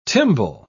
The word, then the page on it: timbal: British English